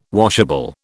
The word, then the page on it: washable: British English